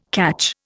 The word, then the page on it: catch: Indian